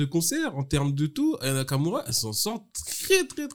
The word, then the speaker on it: Aya Nakamura: Je l'ai très mal prononcé comme Nico Sayalagas. Aya Nakamura, je voulais dire.